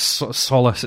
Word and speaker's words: Solas: We've got to work on your pronunciation of the word Solas. It's solace. It's Gaelic solace. For light.